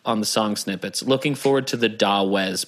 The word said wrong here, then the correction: Dawes: I think that's pronounced Doze. – Oh, is it? – I think so. – Okay. I think it's pronounced Doze.